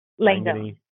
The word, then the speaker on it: Langone: Is it pronounced Langone or Langone or Langone? – Langone.